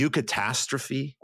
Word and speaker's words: eucatastrophe: The eucatastrophe? – Yeah.